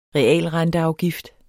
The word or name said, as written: realrenteafgift